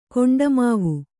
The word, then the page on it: ಕೊಂಡಮಾವು: koṇḍamāvu; Kannada